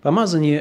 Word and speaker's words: помазание: Помазание – это довольно, понятная вещь, с одной стороны. Вы знаете глагол «помазать». Кстати, ударение в слове «помазание» – это слово «помазание», а не «помазание», как очень часто можно слышать.